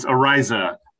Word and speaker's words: Araiza: It's Araiza. My father shared with me, he says, share it like this. He's like the sun arises in the morning.